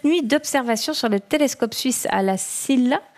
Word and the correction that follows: La Silla: À la SIA. – SIA, ouais, voilà.